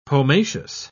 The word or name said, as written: pomaceous